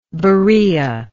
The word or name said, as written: Berean